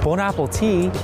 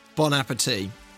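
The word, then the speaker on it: bon appétit: Sorry? Bon apple tea? – Bon apple tea. – Bon appetit. – Well, that's not how you say it though, is it? He's saying bone apple tea.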